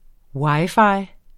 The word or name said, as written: wi-fi